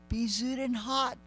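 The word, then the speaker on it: Bezuidenhout: I can't pronounce this. Bezudenhout, -4. – Yeah, that's it.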